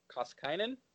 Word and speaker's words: Koskinen: And then you got Mike Smith and Koskinen. Is that how you say it? – Yeah.